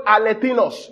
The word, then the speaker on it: alethinos: The word there true, you see, the word true light is the word alethinos in the Greek. I'll spell it for us. A-L-E-T-H-I-N-O-S. Alethinos.